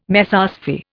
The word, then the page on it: Mesosphere: Indian